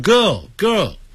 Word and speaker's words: girl: 像 girl, 女孩子 G-I-R-L, 别念成 girl, 叫 girl, school, 好，这种发音要特别记住。